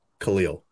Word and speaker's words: Khalil: And they did eventually, but like the running back, for example, Herbert, his name is like, typically when you see that name, you say Khalil. I think that's like, if there's a common pronunciation of that, of K-H-A-L-I-L, it's probably Khalil. But there's like three different variations of that.